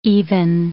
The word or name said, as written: even